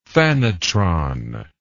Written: phanotron

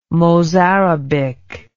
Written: mozarabic

